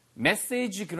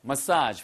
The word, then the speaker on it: massage: 발음 주의하세요, 이거. 메시지 그러면은 전달하는 메시지고요. 예, 그 다음에 전달 사항인 거죠. 얘는 massage예요. massage. 발음은 두 가지입니다.